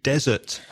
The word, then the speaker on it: desert: Just to be clear, desert is D-E-S-E-R-T, just one S, and desert is with double S, that's postre. And the stress changes. So desert has the stress on the first syllable, desert. Desert has the stress on the second syllable.